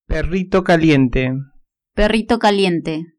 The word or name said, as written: perrito caliente